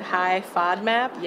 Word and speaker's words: High FODMAP: Is it High FODMAP? – Yes.